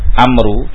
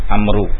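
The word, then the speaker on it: Amr (عمرو): Jadi kalau ada tulisan ain, mim, ra dengan waw, bacanya Amr, Amru. Tapi kalau tanpa waw, bacaannya Umar.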